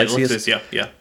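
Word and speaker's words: Lexaeus: So, continuing on, Lexius, I'm saying that right. – Yeah, Lexius.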